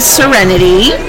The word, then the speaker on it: Serenity: Hi, my name's Serenity, spelled like it sounds, S-E-R-E-N-I-T-Y.